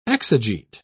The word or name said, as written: exegete